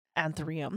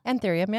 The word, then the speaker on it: anthurium: And there is this plant, and I'm pretty sure it was an anthurium. Am I saying that right now? – Anthurium, yeah.